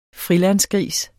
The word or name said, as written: frilandsgris